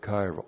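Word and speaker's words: chairo: And then the verb kairo. Always kairoing. Always. C-H-A-I-R, long O.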